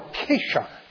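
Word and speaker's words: kashar (כשר): We now say kosher. The Hebrew word is pronounced more like kashar. Kashar is that which is fit, that which is right.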